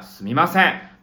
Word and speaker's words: すみません: 各位同学，大家好，我是爱过去的日语讲师。今天为同学们介绍一下死密马赛和死密马赛的区别。那其实同学们严格来说，正确的说法、标准的说法应该是死密马赛。那么只不过因为死密马赛呢发音更加简单，所以说在生活中口语当中呢更加经常使用了。